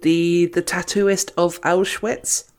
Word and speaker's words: Auschwitz: Sorry if I'm pronouncing the Auschwitz bit wrong. I can never say I can never be sure if I actually say that correctly or whatever. But sounds correct to me.